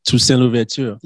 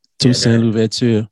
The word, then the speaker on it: Toussaint Louverture: Three topics today is Toussaint Louverture. New York City. – Wait, what? Say that again. – Toussaint Louverture.